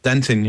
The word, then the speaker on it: denting: You call it denting? Is that how it's said? – Yep, denting, yep.